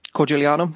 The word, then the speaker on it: Corigliano: Corigliano, that's how you say it.